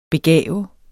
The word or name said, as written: BEGAV